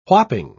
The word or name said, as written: whopping